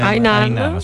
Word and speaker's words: Hainan: Questo incontro appunto tra il territorio di Fiumicino, ma in generale comunque anche del Lazio, con questa isola cinese Hainan, l'ho detto bene? – Hainan, ce l'ho fatta, ok, al secondo tentativo è andata,